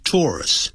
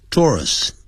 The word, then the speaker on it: tourists: 这位歌手很受到我们的欢迎。这边还是很受到观光客的欢迎。Tourists, 啊这个念成 tourists, 哦 tourists. Australia has something for everyone.